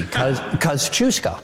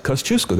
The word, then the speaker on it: Kosciuszko: The first syllable is cuz, like the word cousin. The second one is choose, like choose or lose. Cuz, choose, co. – You made this so easy. Kosciuszko. – Kosciuszko, there you go.